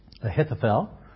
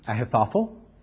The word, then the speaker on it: Ahithophel: Ahithophel, or you can pronounce it Ahithophel, or there's another variation, Achitophel.